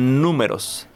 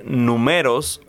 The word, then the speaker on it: números: Números. – Números. – Números. But maybe you can do it incorrectly saying numeros, or you can say numeros, mm-hmm. but the correct way to say is números.